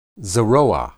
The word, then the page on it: zeroa: hebrew